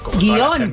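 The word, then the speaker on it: guion: No, porque aunque muchas personas la pronuncian en dos sílabas, la RAE considera que es una palabra monosílaba. Guión. No es guión.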